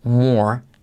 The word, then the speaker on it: more: Quien mucho abarca, poco aprieta es to bite off more than you can chew. More, esa R decae, ¿vale? Se dice así, more than. More than, como more than a feeling, more than you can chew, ¿ok?